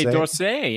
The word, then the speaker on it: Musée d'Orsay: So they go to the, you can help me pronounce this, the Musée d'Orsay. – Musée d'Orsay, yes, in Orsay.